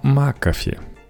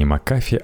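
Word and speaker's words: McAfee: Да, пока я задумывал этот выпуск подкаста, тут еще должна была быть новость про Макафи. Вот только недавно узнал, что оказывается не Макафи, а Макафи. Но хотя бы ударение не как в Эдоби, а то был бы Макафи.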